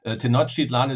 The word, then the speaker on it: Tenochtitlan: Before we get started, is Tenochtitlan. That's how we pronounce that, correct? – Well, actually, nobody knows exactly how it was pronounced, so don't worry about it. And of course, Tenochtitlan is quite good.